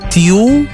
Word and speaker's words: Tiel: En het midden, ja, woon je bijvoorbeeld in, wat zullen we zeggen, in Utrecht of in Tiel, zeg ik dat goed?